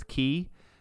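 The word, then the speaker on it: Key (χ): They released Kingdom Hearts Key, and it was like the X symbol that's pronounced like key.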